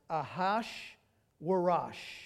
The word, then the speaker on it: Ahashverosh: But I'm going to tell you that the name, if you have a New American Standard, for example, and you see A H A S U E R U S, is to my knowledge pronounced Ahash Warash.